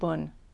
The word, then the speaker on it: Bon: So the Shambhala teachings draw both on Nyingma and Kagyu, as well as on the teachings of the indigenous religion of Tibet, which is called Bun, but it's spelled B-O-N, but it's pronounced Bun.